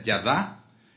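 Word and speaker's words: yadá (ידה): La palabra hebrea para alabanza es odé, de la raíz, y da, Y-D-A-H, se pronuncia yadá.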